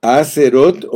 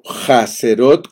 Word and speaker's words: Hazerot: Vamos a hablar de Acerot, Acerot o Jacerot, como quieran pronunciarlo, porque tiene una H.